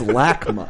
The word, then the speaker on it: LACMA: I believe it's pronounced LACMA.